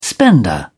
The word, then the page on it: spender: British English